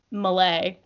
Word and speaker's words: Melle: So my last name is Malay, M-E-L-L-E. – Oh, I'm so sorry. – No, it's cool. Everyone pronounces it differently.